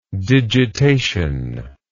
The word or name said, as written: digitation